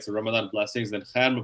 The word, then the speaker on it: Khair: Akhair Mubarak is K-H-A-I-R. There's not really an English language equivalent of K-H, so it's Khair.